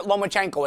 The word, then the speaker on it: Lomachenko: Just pronounce it Lomachenko.